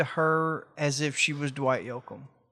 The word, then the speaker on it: Dwight Yoakam: Dwight Yoakam.